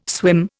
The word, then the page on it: swim: Indian